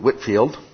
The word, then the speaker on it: Whitefield: There was also a fellow, George, now it looks like Whitefield, I hear a lot of people pronounce it Whitfield, who was associated with the movement.